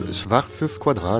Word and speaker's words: Schwarzes Quadrat: Nouveau morceau du copain Sisyphe, c'était, alors pardonnez ma prononciation, Schwarze Quadrat, c'est ça comme ça hein? – Je crois que c'est plus Schwarzes Quadrat. – Ah oui, c'est mieux en effet.